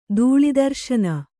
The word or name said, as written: ದೂಳಿದರ್ಶನ